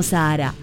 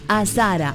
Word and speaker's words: Azara: Sí, como si fuera Sahara, pero no, es en Misiones y se escribe distinto. A-Z-A-R-A. Azara. No Sahara. Azara. Ahora está bien dicho.